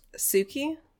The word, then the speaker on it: Tsuki: We also went to Tsuki, which is spelled T-S-U-K-I. – Do you think it's pronounced Suki? – Yes, absolutely. Oh, but they put the T in there, and I'm not out here avoiding consonants, honey.